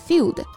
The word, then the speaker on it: feud: 嗯，这个 feel 大家注意了啊，字母 e u 在这里发 u 这个音，所以它读成 feud。